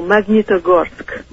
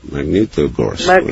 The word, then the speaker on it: Magnitogorsk: Oh, Magnitogorsk. – Magnitogorsk.